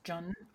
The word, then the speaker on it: Jun: Um, particularly like I think it's pronounced Jun or like J U N, like it's um, they're like made with yes!